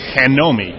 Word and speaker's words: GNOME: How do you guys pronounce it? – Gnome. – Gnome? – Yeah, but if you're in Spain, it's Hanomi, I believe. – Hanomi. – I met some guys, Spanish gnome users at Guadec last year, who kind of were educating me on the kind of correct and Spanish way to pronounce this.